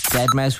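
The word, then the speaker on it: deadmau5: Dead Mouse, which is spelled Dead M-A-U 5.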